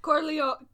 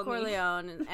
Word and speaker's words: Corleone: There's a family called the Carleones. Is that how you say it? Maybe. – Corleone. – Corleone.